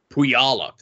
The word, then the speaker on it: Puyallup: Puyallup. – Puyallup.